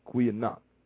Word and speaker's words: goeienag: G-O-E-I-E-N-A-G. Kwe, the G is pronounced Q, Kwe, Kweanach. – Kweanach. – Kwe, it's, see, and it's at like, it's kind of like they put an A onto it, but it's not there. The A is silent, but it's there.